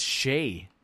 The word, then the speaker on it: Shai: It's not Shai. It's Shai. – Is it? – Yes, it's Shai Gilgeous-Alexander. I've been saying it wrong forever.